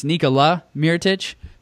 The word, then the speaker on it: Nikola Mirotić: both of you guys missed up on Mirotić. You corrected yourself later in the pod, but we got a Nikola from Ken, and we got a Nikolai from Shannon. It's Nikola Mirotić.